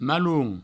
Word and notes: malon: maloun

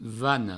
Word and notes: vana: vano

